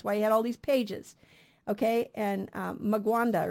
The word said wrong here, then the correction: Mwanga: or something, his name, I don't know how to say it, it's Mwanga, King Mwanga of Uganda.